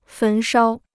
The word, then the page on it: 焚烧: fén shāo